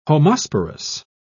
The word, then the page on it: homosporous: British English